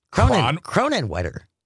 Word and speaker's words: Kronenwetter: Julianne in Cronwetter. Cronenwetter. – That doesn't feel like a place. – Cronenwetter. – Spell that for me. – K-R-O-N-E-N-W-E-T-T-E-R. – It's a village in Wisconsin.